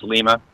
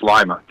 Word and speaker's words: Lima: See, what's kind of intriguing is it's often pronounced Lima, like in Peru, but it's Lima.